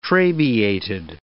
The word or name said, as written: trabeated